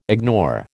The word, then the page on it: ignore: American English